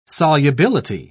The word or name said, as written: solubility